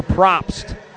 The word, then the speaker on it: Probst: As we just told you, Amoroso departing in favor of Tanner Probst, who makes his second appearance out of the bullpen for Fresno here this series. Probst is how you pronounce his last name, P-R-O-P-S-T, Tanner Probst, 6-foot, 190-pound, undrafted free agent in 2020, inked to a contract with the Rockies.